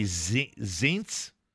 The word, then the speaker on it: Zients: Office of Management and Budget, Jeffrey Zients. – What? Spell it. – Z-I-E-N-T-S.